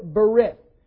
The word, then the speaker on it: berith: Now, the word that's translated covenant is the Hebrew word berith. B-E-R-I-T-H is how you would spell it in kind of an English variation.